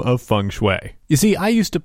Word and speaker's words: Feng Shui: And we pronounced it exactly like that feng shui, the same way that you would pronounce the geomantic exercise of finding harmony in spaces.